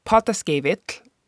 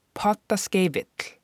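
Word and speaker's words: Pottaskefill: And when you look on my website and you'll see that potta skeivikl, the F sounds kind of like a V. And in Icelandic, whenever an F is in between two vowels, it is pronounced like a V for Victor sound.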